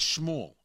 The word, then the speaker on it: Schmuhl: I think it's Schmuel, S-C-H-M-U-H-L.